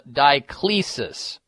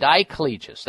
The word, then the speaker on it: Diclegis: called Diclegis, that's how you pronounce it. Diclegis, that's what she's taking.